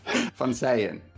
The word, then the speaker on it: van Seijen: I'm Jono Proudfoot, and today we have Koen van Sayen. How do I pronounce that? – Van Sayen, don't worry. – Van Sayen, okay, which is Dutch.